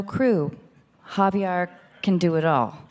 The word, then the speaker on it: Javier: It's Javier. It rhymes with caviar.